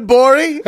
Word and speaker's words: Gborie: That other voice you hear is David Gborie. – Hey. – at the G is silent at Cool Guy Jokes 187.